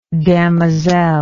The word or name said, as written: damoiselle